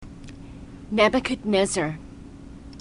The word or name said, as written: nebuchadnezzar